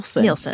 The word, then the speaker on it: Nielsen: I'm just going to go for Nielsen because it's N-I-E-L-S-O-S-E-N. – Nielsen. That would be like Nielsen ratings.